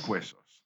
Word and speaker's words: Weysos: Frank Weysos, okay? – Weysos. – Weysos. – Like bones? – Yes. That is the phonetic pronunciation of the Spanish word huesos, which is bones.